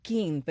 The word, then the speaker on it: Qin: Qin Dynasty se pronunciaba, se escribe Qin, pero se pronuncia Qin, y de ahí entonces viene el nombre de China.